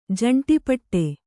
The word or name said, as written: ಜಂಟಿಪಟ್ಟೆ